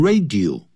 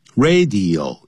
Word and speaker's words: radio: 不过这个到来，什么的到来，这个 arrival 当然就等于 the invention, I-N-V-E-N-T-I-O-N, 发明 of the radio, 不要念成 radio, 三个音节 ,radio, 要念得清清楚楚。